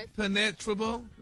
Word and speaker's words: impenetrable: Impenetrable. Uh-huh. Impenetrable. – No, your emphasis is on the wrong syllable.